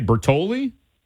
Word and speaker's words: Bertogli: I'm going to say Bertoli? – You are right. – Bertoli. Okay, silent G.